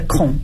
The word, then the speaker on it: Kong: I'm a Cantonese, so usually when people look at my last name, they will pronounce it just like the Mandarin's Hong. That actually is my Confucius, the 73rd generation. So it's a Kong in Mandarin's pinyin.